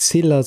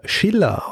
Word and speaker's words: Silla: Und zwar heißt dieses Königreich nicht Silla, sondern es wird entsprechend äh, Schiller ausgesprochen.